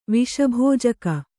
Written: ವಿಷಭೋಜಕ

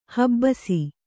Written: ಹಬ್ಬುಸೆ

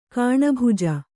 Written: ಗಾಣಬಿಜ್ಜೆ

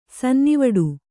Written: ಸನ್ನಿವಡು

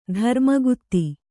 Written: ಧರ್ಮಗುತ್ತಿ

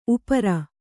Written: ಉಪ್ಪೇರಿ

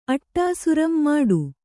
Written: ಮುಟ್ಟ

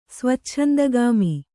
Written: ಸ್ವಚ್ಛಂದಗಾಮಿ

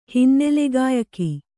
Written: ಹಿನ್ನೆಲೆಗಾಯಕ

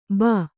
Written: ಭೂ

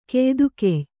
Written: ಕೆಯ್ದುಗೆಯ್

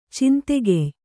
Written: ಛಾಂದೋಗ್ಯ

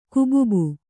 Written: ಕುಬುಬು

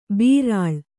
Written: ಬರ್ಲು

